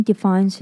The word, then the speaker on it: define: 这边要注意define跟definite这两个字的发音。首先define是重音在fine, define,后面的fine这个字是重音。那另外是definite,definite的重音是在前面, definite。 Definite. 另外要注意的是, 这两个字都是DE, 但这两个DE的发音却不同。 Define的DE是E, define. 另外definite的DE是E, definite.